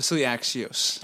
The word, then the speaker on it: Axios: It's uh, Axios.